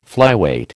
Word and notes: flyweight: American English

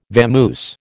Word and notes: vamoose: American English

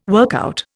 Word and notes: workout: Indian

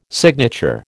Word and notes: signature: American English

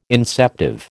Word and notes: inceptive: American English

